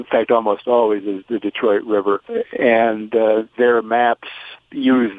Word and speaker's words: Detroit: Dunnigan says he thinks as more British troops were in the area and eventually controlled it, they changed the pronunciation from the French, Détroit, to the English, Detroit, that we use today.